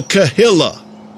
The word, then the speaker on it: Cahilla: Now that's spelled here C-A-H-I-L-L-A, not K-E-H-I-L-L-A. The Kahilla.